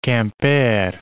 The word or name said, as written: Quimper